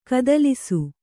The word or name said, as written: ಕತ್ತಲಿಸು